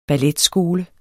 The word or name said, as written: balletskole